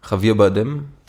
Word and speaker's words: Javier Bardem: Und wenn ich, ich weiß hier nicht genau, Javier Bardem.